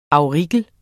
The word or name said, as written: Primula auricula